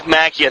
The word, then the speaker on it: Mackey: Our hero of the day is a guy by the name of John McKay. Mackey, I think is probably the right way to say his name. Who is John McKay or John Mackey? Mackey. M-A-C-K-E-Y. That's Mackey.